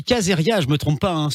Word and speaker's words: Caesaria: On dit Caesaria, ouais. – Caesaria. Ouais, c'est ça. Ça s'écrit comment pour savoir, pour vous aller vous retrouver sur les réseaux C'est C-A-E-S-A-R-I-A, e comme César, Caesar, IA à la fin.